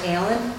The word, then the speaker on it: Ehlen: My name is Barbara Allen. My last name is spelled E-H-L-E-N.